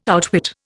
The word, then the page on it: outwit: Indian